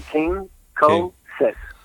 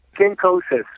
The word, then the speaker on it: Kinkosis: Or guys, I don't know if you guys have Kinkos over there. Do you guys have Kinkos? Like, Levi's a little bit kinky. All right. – So there you go. Just Kinkosis. Kinkosis. That's how you pronounce it.